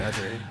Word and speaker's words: DeAndre: And, of course, I think his name is DeAndre Ayton. Is that how you say his name? – DeAndre.